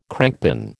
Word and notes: crankpin: American English

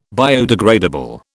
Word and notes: biodegradable: British English

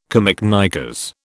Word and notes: camiknickers: British English

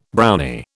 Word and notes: Brownie: British English

